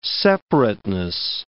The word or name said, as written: separateness